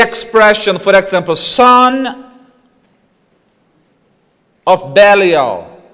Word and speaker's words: Belial: Here Paul mentions Belial. And this is literally how it is pronounced.